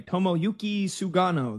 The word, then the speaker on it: Tomoyuki Sugano: Tomoyuki Sugano.